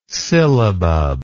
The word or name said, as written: sillabub